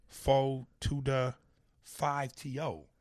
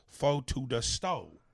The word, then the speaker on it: 4TODA5TO: Foe to the 5 TO. But he's saying it's pronounced Foe to the Stow.